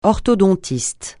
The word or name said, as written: orthodontiste